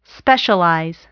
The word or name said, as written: specialize